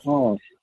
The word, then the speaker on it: Ong: Mr. Wong, did you say his name is? – Dr. Ong. – It's Dr. Ong, O-N-G, Dr. Ong.